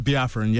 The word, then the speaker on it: Biafran: And then it's Biafran. Is that how you say it? – Mm-hmm. Biafran, yeah.